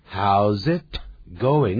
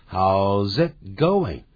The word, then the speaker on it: How's it going: 那个 it，那个 t，是把它音要快要发出 t 的时候，把它切住，不让它发出来，不要念成 How's it going? 那个 t 不念出来的。 How's it going? How's it going? How's it z 那个舌头啊，舌尖就抵在上面。